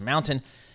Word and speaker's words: MNTN: Let's get to the bottom of that with Mark Douglas, founder and CEO of MNTN, pronounced Mountain, based in Los Angeles.